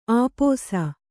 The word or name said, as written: ಆಪೋಸ